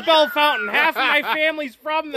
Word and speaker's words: Bellefontaine: No, that's the whole thing behind Bell Fountain. It's spelled Bellefontaine. – Like Fontaine? – Yeah. – Yeah, but it's Bell Fountain.